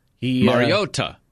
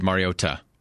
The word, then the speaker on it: Mariota: That's how he pronounced it. – Apparently he does pronounce it like that. – Yeah, he really stuck the landing on the T there. – Yeah, they asked him about that, and he said Mariota.